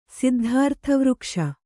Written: ಸಿದ್ಧಾರ್ಥವೃಕ್ಷ

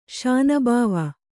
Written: ಶಾನಬಾವ

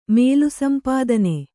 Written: ಮೇಲುಸಂಪಾದನೆ

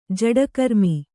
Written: ಜೋಡಿಗ್ರಾಮ

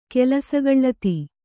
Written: ಕೆಲಸಗಳ್ಳತಿ

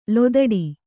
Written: ಲತಾಡ

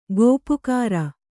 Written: ಗಪ್ಪುಗಾರು

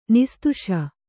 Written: ನಿಸ್ತುಷ

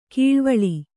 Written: ಕಳವಳ

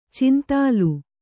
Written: ಚೆಂದಲ್

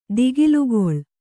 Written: ದಿಗಿಲುಗೊಳ್ಳು

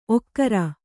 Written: ಓಕರ

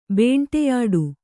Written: ಬೇಂಟೆಯಾಡು

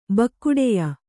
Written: ಬಕ್ಕುಡೆಯ

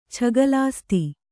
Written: ಛಗಲಾಸ್ತಿ

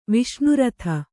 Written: ವಿಷ್ಣುರಥ

